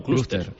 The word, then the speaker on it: clúster: Pero sí, efectivamente, nosotros que somos españoles le hemos llamado, como se dice en español, con acento en la U, clúster marítimo español.